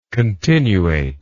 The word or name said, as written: continuate